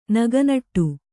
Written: ನಗ್ನಾಟ

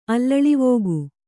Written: ಅಲ್ಲಳಿವೋಗು

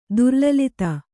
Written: ದುರ್ಲಲಿತ

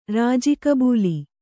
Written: ರಾಜಿಕಬೂಲಿ

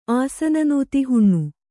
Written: ಆಸನನೂತಿಹುಣ್ಣು

